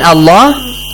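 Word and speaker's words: Allah (الله): A word ends with Fatha, then Allah. So Allah must be pronounced heavily.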